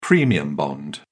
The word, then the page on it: premium bond: British English